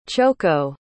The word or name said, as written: chouko